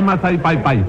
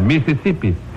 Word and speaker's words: Mississippi: ¿Qué es ese ¿Qué es lo que es Maizai Pai Pai? – La I no se pronuncia ahí.